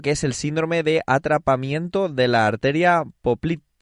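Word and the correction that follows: poplítea: Poplitea, creo que se dice así.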